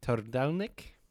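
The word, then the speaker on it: Trdelník: I think it's called Tordelnik. But it is. – That's pretty well pronounced.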